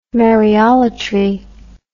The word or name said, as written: Maryolatry